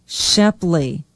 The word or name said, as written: Shepley